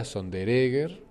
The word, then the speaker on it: Sonderegger: En realidad se pronuncia Sonderegger, como lo decimos, sí, pero se escribía Sondere, doble G-E-R.